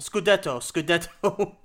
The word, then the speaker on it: Scudetto: Or I hope I said that right, by the way. – No, Scudetto. – Scudetto. Sorry.